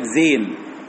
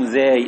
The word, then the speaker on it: زاي: وده النطق الصحيح، لان بعض الناس يقول لك زين، زين دي غير صحيح، انما هو اسمها الحرف ده اسمه ايه؟ الحرف اسمه زاي، اسمه ايه؟ اسمه زاي، ماشي.